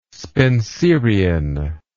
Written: spenserian

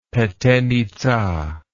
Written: petenitza

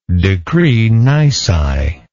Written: decree nisi

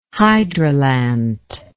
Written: hydrolant

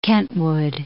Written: Kentwood

